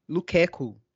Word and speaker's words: Lukaku: Na verdade, eu, eu que sou um maluco aqui de pronúncia, até peguei o jeito certo, é Lukaku, de se pronunciar.